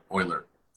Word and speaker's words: Euler: Euler. And it's E-U-L-E-E-R.